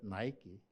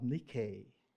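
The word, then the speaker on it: Nike (νίκη): I used to pronounce that Nike, the shoes. I just found out it's pronounced Nike, which means conquest or victory.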